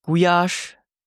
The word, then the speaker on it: Gulyás: Es wird ausgesprochen in Ungarn Gulyás.